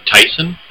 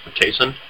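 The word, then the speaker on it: Taisen: Ninja Tyson, I believe is how you pronounce it. Tyson. – Mm-hmm. – It's T-A-I-S-E-N.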